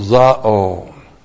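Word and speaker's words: Zao (ζάω): It's modified by the adjectival participle zao. Looks like that in the Greek. Z-A long O. Zao.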